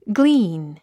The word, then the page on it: glean: glíːn; グリーン